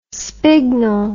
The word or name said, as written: spignel